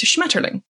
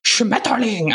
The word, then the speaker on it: Schmetterling: In Germany, it's Schmetterling, which is not an ugly word inherently. It's just your bias against the German language makes you pronounce it in an ugly way.